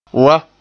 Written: wan

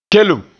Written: kelem

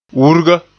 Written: wurga

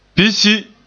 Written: pisyi

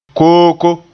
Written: kooko

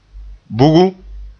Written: bugum

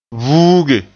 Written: vuuge

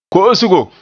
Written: ko'osgo

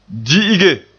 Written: di'ige

